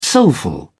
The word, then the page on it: soulful: British English